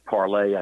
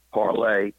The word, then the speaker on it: Parler: or it's actually Parlay, I think is the way you're supposed to pronounce it, P-A-R-L-E-R, Parlay, that, instead of Facebook.